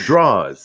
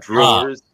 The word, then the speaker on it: drawers: And it's draws. Say it right. Draws, not drawers. You put draws in drawers.